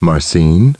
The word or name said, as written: Marcene